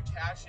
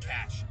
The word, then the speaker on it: cache: Oh my god, I found a cache of Greek records that we've been decorating our wall with. – It's just cache. It's just cache. You don't pronounce the.